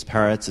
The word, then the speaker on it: Peretz: You know, my name is Peretz. It's spelled P-E-R-E-T-Z. A lot of people think I'm saying other things. Paris, you know, most non-Jews get it like screwed up and stuff like that.